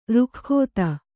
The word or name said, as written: ರುಖ್ಖೋತ